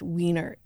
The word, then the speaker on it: Weiner: Anthony Weiner is famous, I mean, for aside from the other stuff, he's famous for saying, my name is pronounced Wiener.